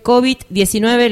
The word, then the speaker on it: COVID-19: Se afirma que el COVID-19, ¿lo dije bien? – Sí, correcto.